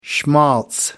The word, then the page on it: schmaltz: American English